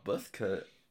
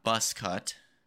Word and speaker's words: buzz cut: Buzz cut, not bus cut. – How do you say it? – Buzz cut. – Buzz? – You've been saying bus cut the whole time? – Like buzz cut. – Buzz cut. – Buzz cut. – Because it's a buzz.